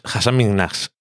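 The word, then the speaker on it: Hasan Minhaj: Y decía que es Hassan Minhash. – Minhash. – Sí, que la H es sonora. – Ok, pues Hassan Minhaj.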